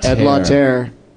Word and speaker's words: Ed Lauter: Ed Lauter, and I'm pronouncing it right. – Ed Lauter was okay.